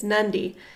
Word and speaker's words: Nadi: So the city that we flew into is spelled N-A-D-I, and we were calling it Nadi, and everyone's like, what are you talking about? – Nadi. – And then I think it was Tyler Hornsby, was like, yeah, there's a silent N, it's Nandi.